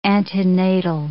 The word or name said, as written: antenatal